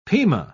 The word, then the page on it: pima: British English